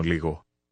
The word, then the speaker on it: λίγο: این صدا شبیه جی در کلمه انگلیسی گو و همینطور وای در کلمه انگلیسی یسه. حالا دوباره بگید. یکمی. – لیگو. – بگید یکمی متوجه میشم. – Καταλαβαίνω λίγο.